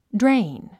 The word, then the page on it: drain: dréin; ドレイン